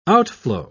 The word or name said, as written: outflow